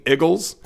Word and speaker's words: Iggles: Eagles. And it's spelled I-G-G-L-E-S.